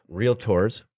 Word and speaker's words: Realtors: because I'm a representative of the Association of Realtors, Realtors, two syllables. – Tor. – Realtors. – Not realtor.